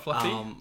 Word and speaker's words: fluff: It's pronounced fluff, by the way, for anyone wondering. – Okay, not fluffy. – No, it just sounds better, like, as a noun than an adjective.